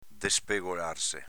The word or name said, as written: despegolarse